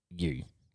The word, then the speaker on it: Sadhguru: So that's Sadhguru.